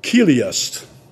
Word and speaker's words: Chiliast: And they're also called Kiliast. You'd spell that Chiliast, apostrophe S.